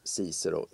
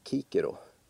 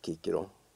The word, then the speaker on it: Cicero: Väldigt välkänd gammal författare som alltid har uttalats Cicero i Sverige. Men om man ska vara korrekt så heter han egentligen Kikero. C ska egentligen uttalas som K.